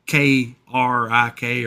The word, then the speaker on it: creek: And well, how do you say the word C-R-E-E-K? – Oh, well, I say creek, but right. Some people say K-R-I-K or K.